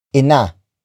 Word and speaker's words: ina: It is the focus marker, which introduces the main noun of this sentence, which is ina. Ina. Ina. Ina is spelled as I-N-A.